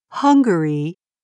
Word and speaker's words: Hungary: If you listen carefully, the two words are pronounced slightly differently. Hungary and hungry.